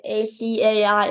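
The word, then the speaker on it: açaí: And though it's spelled acai, A C A I, it's actually pronounced acai.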